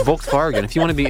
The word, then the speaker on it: Volkswagen: It's not Volkswagen. It's Volkswagen. That's the correct way to say it.